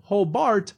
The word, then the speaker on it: Hobart: Because it is spelled Hobart, but it is pronounced Hobart.